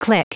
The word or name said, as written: clique